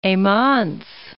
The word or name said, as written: Heymans